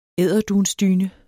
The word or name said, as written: edderdunsdyne